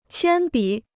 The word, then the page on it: 铅笔: qiān bǐ